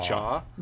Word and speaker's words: Zhao: So I think we should start with our history with, I thought you pronounced her name Chloe Zhao, but I'm hearing I think it's pronounced Jaw.